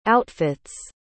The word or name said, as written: outfits